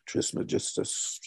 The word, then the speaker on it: Trismegistus: this figure of Hermes Trismegistus, the, uh, or uh, however you would pronounce that in English, Trismegistus.